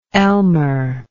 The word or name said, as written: Elmer